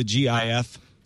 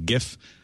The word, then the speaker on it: GIF: which quotes the specs of the GIF specification. The pronunciation of GIF is in the spec to be GIF, as in Jiffy, rather than GIF, which most people seem to prefer.